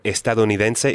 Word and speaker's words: estadounidense: Okay, so estadounidense is American.